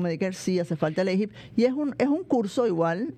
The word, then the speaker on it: AHIP: El AHIP, se escribe A-H-I-P, que es el AHIP.